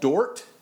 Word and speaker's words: Dordt: And this met in a little small town called Dort in the Netherlands there, D-O-R-D-T or something, spelled like a Dutch word, like you can imagine.